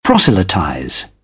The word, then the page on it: proselytize: British English